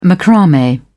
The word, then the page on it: macrame: British English